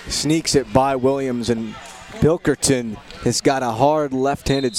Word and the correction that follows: Pilkerton: For some reason, I like to butcher her name. It's Pilkerton, Pilkerton, Pilkerton.